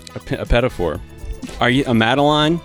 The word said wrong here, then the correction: petit four: How do you say that? – This is the second time you've asked me one of these questions. A petit four. Petit four.